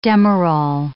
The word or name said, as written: Demerol